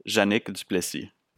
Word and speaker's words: Janique Duplessis: With my best French-Canadian accent, it would be Janique Duplessis.